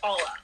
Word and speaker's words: Paule: So, well, first of all, so my nickname is Paula, right? – Paula. – But officially, I'm Paul, and that's spelled P-A-U-L-E, right? – Okay.